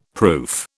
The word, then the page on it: proof: British English